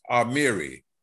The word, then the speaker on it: Amiri: Amiri, and it's pronounced Amiri.